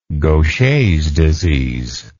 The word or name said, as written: Gaucher's disease